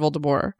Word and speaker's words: Voldemort: So J.K. Rowling pronounces Voldemort Voldemort. She always has.